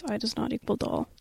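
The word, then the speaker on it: I ≠ DOLL: I believe it is I Does Not Equal Doll. – Okay. – Because it's like idol, but it's not, I'm not your doll.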